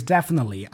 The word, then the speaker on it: definitely: Notice that he doesn't pronounce the T. He doesn't say definitely, nothing like that. Imagine there's no T there, and we just hear the N sound.